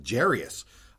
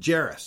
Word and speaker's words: Jairus: First thing I learned was that I've actually been pronouncing his name wrong for a lot of years. I always thought it was Jairus, but it's Jairus, so that's my bad.